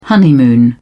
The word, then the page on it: honeymoon: British English